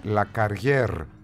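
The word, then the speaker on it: Lacarrière: Λακαριέ προφέρεται λέει με ρο στο τέλος, λέει ο Κώστας, είναι Λακαριέρ, μάλιστα, αφού σας είπα τα γαλλικά μου, σε ευχαριστώ πολύ Κώστα.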